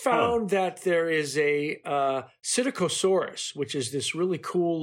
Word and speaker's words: Psittacosaurus: I think we had agreed early on in a previous show that it was Psittacosaurus.